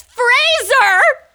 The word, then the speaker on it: Fraser: You just Googled how to pronounce Brendan Frazier. – It's Fraser. He's very upset about it, which, by the way, The Mummy is a very good movie.